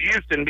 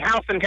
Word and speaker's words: Houston: I know a lot of people will see it and probably pronounce it as Houston because that's the way it's spelled. But it's actually Houston County High School.